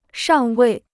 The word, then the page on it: 尚未: shàng wèi